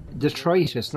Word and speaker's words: detritus: Let's do the Dietrich interrogation. You did it. We know you did it. – Detritus, not Dietrich.